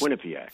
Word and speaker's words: Quinnipiac: Say Quinnipiac. Do me a favor. Say Irish. Quinn. – First. – Quinnipiac. – First syllable emphasis. Quinnipiac.